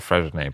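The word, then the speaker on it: Freixenet: And we were, I remember just reading one of the descriptions of, I don't know if I'm saying this right, Frescent champagne, the Cordon Negro. Is that correct? – I think it's called Fresnay, but...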